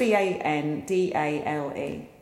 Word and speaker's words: Bendalé: Bendalé.